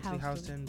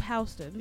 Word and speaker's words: Houston: When I was going into, it's actually Houston, but you know, I so many interviews, I just, after a while, I just Okay, but we'll say it correctly here. South of Houston.